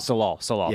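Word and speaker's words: Tsalal: It's like Danvers shows up at Talal. Am I saying that right? – Salal.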